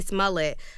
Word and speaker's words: Smollett: Speaking of that, Jesse Smollett was attacked by MAGA supporters in Chicago subway.